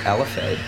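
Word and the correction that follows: enfilade: Is that correct? – The N comes first, though. Infilade.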